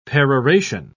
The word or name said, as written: peroration